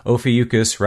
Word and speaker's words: Ophiuchus: And if you do need a reminder as to how to pronounce it, Ophiuchus rhymes with mucus. So for me, that's kind of what helps me remember that.